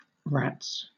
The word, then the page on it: rats: British English